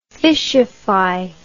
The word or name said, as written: fishify